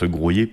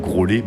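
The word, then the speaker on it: Graulhet: Ben, j'aime bien expliquer que voilà, Groyer, en fait, ça se prononce Groyer parce que dans le nom de cette commune, il y a les lettres LH qui se prononce lieu, c'est Grauliet en occitan. Et justement, cette particularité vient de l'occitan et a été conservée surtout. Peu de personnes, j'espère, ne prononcent Grolet, même si ça doit exister.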